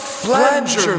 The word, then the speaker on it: flanger: Dost thou have any flanker? – Wait, what? – Flat? – I think it's pronounced flanger, my spectral friend.